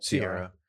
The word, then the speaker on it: Ciara: Ciara.